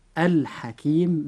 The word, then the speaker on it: الحكيم: فإذا وقع أي حرف من هذه الحروف بعد اللام في أي كلمة فتنطق هذه اللام عن طريق الإظهار، فنقول مثلا في كلمة الحكيم الحكيم بإظهار اللام، لأن الحاء وقع بعد حرف اللام في هذه الكلمة.